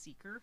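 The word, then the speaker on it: Sekr: It used to be called the Van Life app, and then they rebranded recently to Seeker, and it's S-E-K-R with like a little like line above the E, so it's pronounced Seeker.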